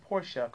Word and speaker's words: Porsche: Hawking Designs likes how you pronounce Porsche correctly.